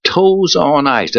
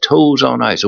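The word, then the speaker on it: Toews on Ice: Well, last Saturday, Del Mar, the Grade 3 Bob Hope Stakes, was won by Toes on Ice. That's spelled T-O-E-W-S, Toes on Ice.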